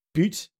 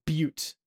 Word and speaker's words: butte: So I referred to something as a butte, and you referred to it as butte. And apparently you were right. And mine is probably because that word is etymologically from French. And so I followed the French pronunciation and you followed the pronunciation.